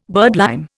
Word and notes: birdlime: Indian

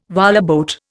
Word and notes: whaleboat: Indian

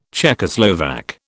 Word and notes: Czechoslovak: British English

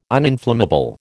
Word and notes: uninflammable: American English

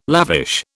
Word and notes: lavish: British English

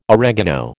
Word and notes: oregano: American English

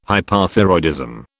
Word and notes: Hypothyroidism: British English

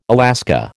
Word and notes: Alaska: American English

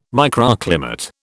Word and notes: microclimate: British English

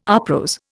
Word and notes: uprose: Indian

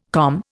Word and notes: COM: Indian

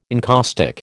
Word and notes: encaustic: American English

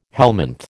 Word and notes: helminth: American English

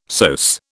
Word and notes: psoas: British English

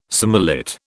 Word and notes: surmullet: British English

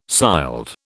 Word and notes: sild: British English

